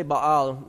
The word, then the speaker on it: Baal: Now I say Baal not to say, oh look, I'm pronouncing his name correctly. No, I'm saying Baal because there's actually another god named Bel. B-E-L. So when we say Bel, I don't want to confuse it with Bel, because I'm going to mention Bel, B-E-L, and Baal, B-A-A-L.